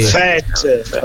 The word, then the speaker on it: Scuffet: Tutta la sera glielo dico, gli dici si chiama Scuffet, non è francese, Scuffet, non si chiama Scuffet. – Scuffet, oh, è friulano.